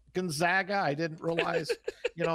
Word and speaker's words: Gonzaga: Yes, to my knowledge, it is Gonzaga. And the reason is they're also known as the Zags. They're not known as the Zogs.